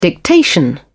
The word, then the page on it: dictation: British English